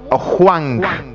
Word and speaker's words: Hwang: Hwang. – Con J, ¿verdad? – Con G. H-W-A-N-G. Hwang. Señor. – Y la H con W en coreano se pronuncia como J, ¿verdad? – Exacto.